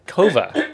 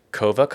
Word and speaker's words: Coava: Coffee by a company called Coava.